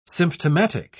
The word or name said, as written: symptomatic